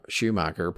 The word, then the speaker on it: Schumacher: And I feel he's probably right that if it wasn't for Schumacher putting him, did I say that right? – Yeah, you got it.